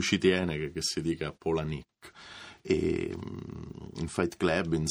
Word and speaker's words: Palahniuk: In effetti si dovrebbe dire Palahniuk, perché erano i nomi dei nonni, Pola, esatto. E Nick. Molti, esatto, dicono Palahniuk, però...